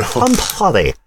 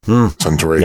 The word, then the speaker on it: Suntory: Sun-pori. So why don't we move on? Wow, that was kind of a racist thing I did just there, wasn't it, with the way I pronounced Suntory. – Suntory.